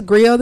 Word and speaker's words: Greo: So my name is Griot the Storyteller. What up? It's spelled G-R-E-O, but it's based off, the original word is G-R-I-O-T, and they are ancient West African storytellers, poets, and musicians.